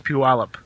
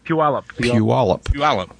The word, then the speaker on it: Puyallup: It's Puyallup. – One more time. – Puyallup.